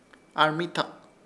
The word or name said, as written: ARMITAK